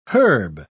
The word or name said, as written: herb